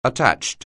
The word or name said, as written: attached